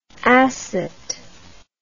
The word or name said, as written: acet